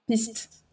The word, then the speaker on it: piste: It was, I don't know how to pronounce it in French, but it's tracks in English. – And in French, it's piste.